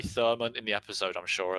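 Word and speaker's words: Thurmond: I can't quite remember the spelling or the pronunciation, but is it Senator Thurman? – Yeah, it is. I didn't realise. I googled him afterwards, and there's a D on the end, so it's Thurman.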